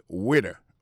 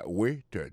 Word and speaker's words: waiter: Alors moi, je prononce le waiter. Waiter, ça veut dire accent tombé sous le premier syllabe, waiter. W-A-I-T-E-R.